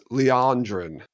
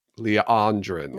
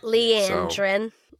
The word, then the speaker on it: Liandrin: And for those of you who are wondering, the book has it pronounced Liandrin. All right? Liandrin. – Not Liandrin.